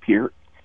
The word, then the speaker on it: Peart: I've always said Peart, and no one has given me any pushback on that. So I stick with Peart.